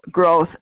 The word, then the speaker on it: Groth: Gross, G-R-O-S-S? – No, Groth, G-R-O-T-H. – At Ace, A-C-E group, spell it out.